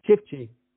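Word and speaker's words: Çiftçi: and Nida Sifke, you said, was it? – Chifchi. – That's the Cork accent coming out.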